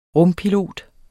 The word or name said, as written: RUMPILOTER